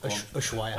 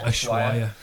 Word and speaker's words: Ushuaia: Ushuaia. – Ushuaia, yeah.